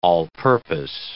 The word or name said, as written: all-purpose